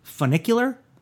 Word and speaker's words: funicular: And hopefully I'm saying this right. Funicular? – Yeah. – Does that sound right? – That's right.